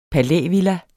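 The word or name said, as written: palævilla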